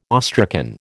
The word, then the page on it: awestricken: American English